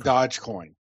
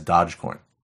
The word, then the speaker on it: Dogecoin: I call it Dodge Coin. – Okay, since you are the virtual. – I understand that may not be the correct pronunciation, but I've never heard anybody pronounce it. – Okay, well, until such a time as we have an official pronunciation on the matter, we will refer to it as Dogecoin.